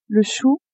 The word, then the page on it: chou: female